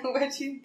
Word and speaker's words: watching: Escuchas, identificas bien el shh, SH. Washing. Watching.